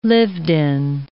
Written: lived-in

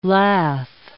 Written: lath

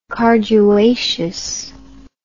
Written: carduaceous

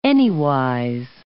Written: anywise

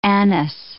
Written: anise

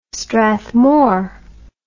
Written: strathmore

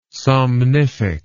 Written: somnific

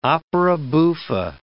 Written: opera buffa

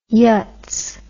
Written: yutz

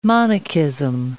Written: monachism

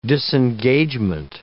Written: disengagement

